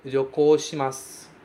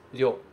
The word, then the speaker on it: ryokou shimasu: Bom, nosso verbo viajar aqui é ryokou shimasu, certo? Ryokou shimasu. Cuidado com essa pronúncia do ryo, que é uma pronúncia um pouquinho difícil. Ryokou shimasu. Ryo. Ryo. Ryokou shimasu.